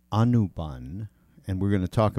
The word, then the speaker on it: Anubhav: All right, you say it properly and tell us what it means. – Anubhav.